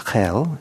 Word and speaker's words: Geel: I think it's pronounced something like Geel. It's spelled G-E-E-L.